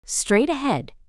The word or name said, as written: straight ahead